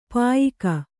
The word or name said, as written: ಪಯ್ಕ